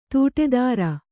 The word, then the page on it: ತೋಟದೂರ: tōṭa dūra